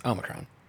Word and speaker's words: Omicron: Omicron. – Omicron. – Or is there an N in there? It's not no. Omicron. – No, it's Omicron.